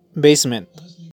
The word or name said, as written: basement